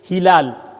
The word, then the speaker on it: ہلال: زیر کے ساتھ بولتے ہیں جو چاند کے متعلق ہے ہلال اور اس کو حلال زبر کے ساتھ اس کا حرف بھی دوسرا ہے اس کا حرف بھی دوسرا ہے تلفظ بھی مختلف ہے